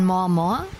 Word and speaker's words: MorMor: Musik aus Kanada von More More. Schreibt man M-O-R, M-O-R.